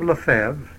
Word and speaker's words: Lefebvre: Now Bishop Lefebvre, that's the pronunciation that I use, Bishop Lefebvre has not so far, and it seems likely that he never will, consecrate another bishop.